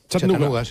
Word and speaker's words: Chattanooga: ¿Se pronuncia así en Chattanooga? – Chattanooga.